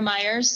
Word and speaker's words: Myers: Under Michael and Sierra Myers, so everybody's more than welcome to contact us that way.